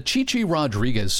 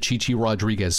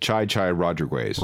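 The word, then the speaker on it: Chi Chi Rodriguez: And when you're reading the news, I read the first news story, and in the back of my head, the Chichi Rodriguez segment on WKRP was going through my head, where he pronounces it Chai Chai Rodriguez.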